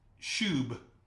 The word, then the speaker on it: shuwb: It's pronounced shub. That's the pronunciation for it, in case you're wondering. Shub.